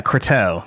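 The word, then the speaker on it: Cortot: That's right, we are back, and today we are going to talk about Alfred Cohart. – Courteau, that's what it is. – I looked it up. – Yeah. – He's French. They don't pronounce like a half. – Yeah, that's right, Courteau.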